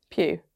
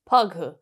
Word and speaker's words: Pugh: Is it Pugh? – Pugh. It's Florence Pugh. – How else would you say her name? Pug-hoo.